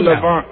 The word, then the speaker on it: Navon: It's called Navon. – Slow down, please. – The new alcoholic drink called Navon. – Why are you mentioning that to us? – Which rhymes with savant.